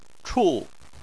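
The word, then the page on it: chu: tone 4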